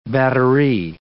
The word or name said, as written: batterie